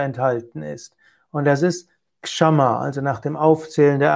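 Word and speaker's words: Kṣamā: Es ist übrigens auch wichtig, dass man ausspricht Kshama, auch wenn ich zugegebenermaßen das häufig falsch ausgesprochen hatte. Kshama ist eben ein weibliches Substantiv und heißt Geduld, Langmut, Vergebung. Kshama mit 2 kurzen A ist ein Adjektiv und das heißt geduldig, ertragend, aushaltend und auch angemessen.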